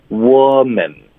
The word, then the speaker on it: woman: 所以针对单数的，它的发音是窝，是一个呃的音，woman。